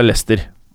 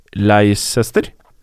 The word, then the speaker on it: Leicester: Hvis du aldri hadde hørt noen uttale Lester, og du mm. bare ser hvordan det er skrevet, hadde du sagt Leicester? – Ja, ja.